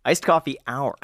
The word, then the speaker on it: Iced Coffee Hour: So he and Jack and I just um, just did a version of his Ice Coffee House. And did I say that right? Iced coffee hour. Hour.